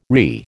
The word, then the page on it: RE: American English